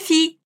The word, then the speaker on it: fille: Enquanto fille a gente pronuncia como se fosse um i comprido, nouvelle a gente precisa pronunciar os L's.